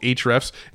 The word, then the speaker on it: Ahrefs: And it's spelled Ahrefs. It's called Ahrefs.